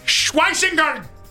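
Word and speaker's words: Schwesinger: And 94, I think this is Anthony Allant's favorite player, Carson Scheisweisinger. Linebacker out of UCLA. But I think it's Schweisinger. That's how you say it.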